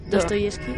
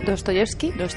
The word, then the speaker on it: Dostoyevsky: Dostoyevsky. – Dostoyevsky.